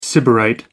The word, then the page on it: sybarite: American English